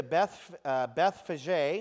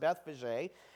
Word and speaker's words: Bethphage: When he drew near to Bethphage, is how it's pronounced, Bethphage,